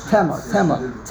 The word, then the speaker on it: Tema: Did I pronounce it correctly? – Tema. – Tess Ayin Mem Aleph. Tema.